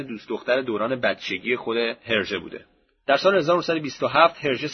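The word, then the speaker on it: Hergé: در سال 1924 جورج رمی نام اختصاری هرژه رو که مخفف اسمش آر جی بوده به تلفظ فرانسه برای خودش انتخاب میکنه.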